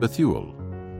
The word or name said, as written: Bethuel